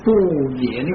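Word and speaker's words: 般若: 慧咧即係智慧，咁啊即係第六種，即係六道中最後一種，就叫做波野。嗱，咁啊波野本來就係印度話，因為有好多嘅意思，所以就不翻而保持呢個波野呢個發音。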